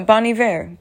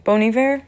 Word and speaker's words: Bon Iver: This man recites this poem and Bon Iver made... Do I say it wrong? No, Bon Iver. Bon Iver? Yes.